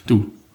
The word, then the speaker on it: Doubs: Am Fluss Doubs, alle französischen Worte. – Doubs und alle französischen Worte lassen sich mit zwei Buchstaben aussprechen. – Du.